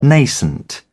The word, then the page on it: nascent: British English